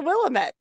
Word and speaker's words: Willamette: We got to see the Willamette River, and I realized I had been mispronouncing it my entire life. I always thought it was the Willamette. And then I got out here and the Oregon natives told me it's the Willamette.